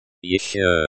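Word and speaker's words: ещё: Еще.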